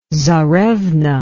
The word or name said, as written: tsarevna